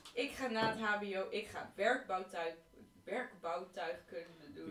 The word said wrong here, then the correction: werktuigbouwkunde: Je kan het nog steeds niet normaal uitspreken. Het is werktuigbouwkunde.